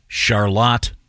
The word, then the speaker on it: Charlotte: Yes, it is in fact pronounced Charlotte, Michigan.